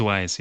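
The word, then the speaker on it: Wwise: I think it's Wise, yeah.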